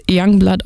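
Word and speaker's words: Yungblud: Aber mit dem Doncaster-Slang wird Youngblood ausgesprochen,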